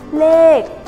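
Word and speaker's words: lê (เลข): À, số tiếng Thái đọc là lê lê lê à, ở trên bảng duyên ghi là là lết nhưng mà các bạn hãy kéo dài ra nha bởi vì à,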